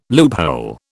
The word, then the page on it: loophole: British English